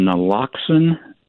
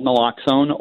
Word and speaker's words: naloxone: I guess there's another a medication called naloxone. Did I pronounce that correctly? – Yes. – It helps to reverse overdoses to opioids. – Yep, there's a medication. It's called naloxone, or the brand name is Narcan.